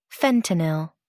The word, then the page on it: fentanyl: American English